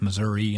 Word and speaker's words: Missouri: In fact, when I took the oath of office, I said both Missouri and Missouri, apparently.